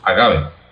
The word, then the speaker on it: agave: proveniente de una planta que es el agave o agave. Yo lo pronuncio agave.